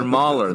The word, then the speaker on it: Mueller: Am I saying it wrong or something? Is it Mueller? – You should make that a t-shirt. No, they're Mueller. The Mueller twins.